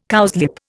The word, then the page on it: cowslip: Indian